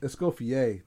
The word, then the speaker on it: Escoffier: It is organized by, I'm going to butcher this because I never took French, Les Dames de Escoffer, D'Escoffer. Escoffer. Is that how you were pronouncing it? – Sounds all right to me. – Organized by Les Dames de Escoffier. The speaker series/Zoom meetings include Escoffier.